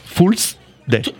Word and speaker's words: Fool's Day: Fool's Day. – Tout à fait, bonne prononciation, bravo.